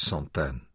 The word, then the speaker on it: Santaine: "This is Santaine." He pronounced it in the English way. "Her mother." Anna touched the other. "This is Santaine." She corrected his pronunciation.